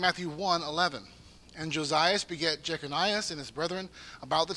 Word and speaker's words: Jeconias: Okay, so this man, Jeconias, as it's pronounced here in Matthew, is Jeconiah is another way to pronounce it. The Bible spells it different ways, but his name is Jeconiah.